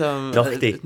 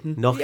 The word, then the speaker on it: Naughtie: Is that how he pronounces it? – I like to think, yes, it's the it's the Scot in me. But I'm sure that... – Naughtie. Naughtie. – Yeah, exactly.